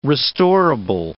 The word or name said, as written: restorable